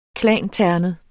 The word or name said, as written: klanternet